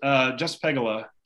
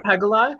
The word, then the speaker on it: Pegula: Pegula? – Oh, just Pegula, Jessica. Yeah. – Just Pegula.